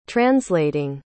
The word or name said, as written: translating